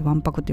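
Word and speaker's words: 腕白: 腕に白いって書いてわんぱくって読むんですね。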